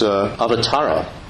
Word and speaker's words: avatar: But if you think about it, avatara, that is the Sanskrit word which in Hindi is pronounced avatar.